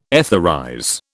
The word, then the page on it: etherize: British English